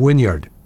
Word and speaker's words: Wynyard: So I guess I've been saying Wynyard instead of Wynyard. Yard. Yard. I mean, and it makes sense. It's spelled W-Y-N-Y-A-R-D. I don't say I was playing in the backyard yesterday. I don't say that. I'm pretty sure I don't. But for some reason, I say Wynyard when it's pronounced Wynyard.